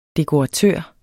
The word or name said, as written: dekoratør